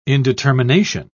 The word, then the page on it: indetermination: British English